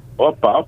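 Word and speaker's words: Opa (ώπα): It's oopa? – Opa, opa. – Oop. Can you say it? Say it. – No, opa, opa. – Opa. – Say it. – Opa. – Opa, yes. – Opa. – O-P-A. O-P-A.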